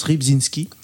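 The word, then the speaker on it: Rybczyński: je ne redirai pas son prénom, mais je pense que son nom de famille se prononce Ribzinski.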